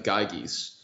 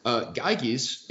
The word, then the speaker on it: Gyges: So in book two, he discusses an allegory about the Ring of Gyges. – Okay. – Which I hope I'm pronouncing that right. It's G-Y-G-E-S.